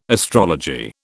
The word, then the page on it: astrology: British English